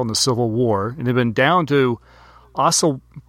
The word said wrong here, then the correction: Osawatomie: Oof, should have looked up how to pronounce this city. Osawatomie.